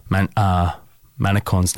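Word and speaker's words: Manchán: If you're curious to look it up, Manacorn's name is spelt M-A-N-C-H-A-N, like Manchan, kind of. And Magan is M-A-G-A-N, Manacorn Magan.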